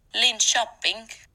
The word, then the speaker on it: Linköping: Linköping.